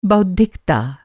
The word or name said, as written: बौद्धिकता